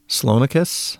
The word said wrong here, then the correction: Cleonicus: C-L-E-O-N-I-C-U-S. – Cleonicus. – C-L-E? Cleonicus. – C-L-E? – Yeah. – Yeah. That's got to be a Cle sound.